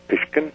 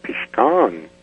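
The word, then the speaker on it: Pishkun: It's a Blackfoot word that means corral, and it's pronounced Pishkin. – Oh, that's interesting. Then the word itself probably was used in the corralling of the animals. – Yes, and see, that's not even correct, but some of the bloods that come down from Canada that are members of the Blackfoot tribe, they say it's actually pronounced Pishkan.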